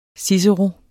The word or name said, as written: cicero